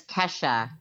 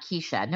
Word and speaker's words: Kesha: My name is Kesha. Kesha, not Keisha. No one called her Keisha? – No one called you Keisha.